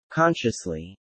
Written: consciously

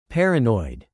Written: paranoid

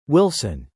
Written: Wilson

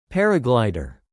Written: paraglider

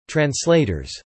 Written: translators

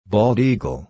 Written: bald eagle

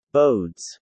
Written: bodes

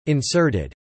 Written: inserted

